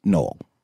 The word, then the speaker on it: Noel: And I do often get people thinking my name is spelled KNOLL, like the grassy one, you know. But that would just be for cool. Anyone out there, let's just put this to bed. It's NOEL. Okay. It's like Noel, but pronounced Noel.